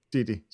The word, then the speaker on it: Didi: Didi. – Didi.